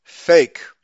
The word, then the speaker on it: PHEIC: The ap- acronym is P H E I C, and that is pronounced fake, as they pose a significant global threat, supposedly.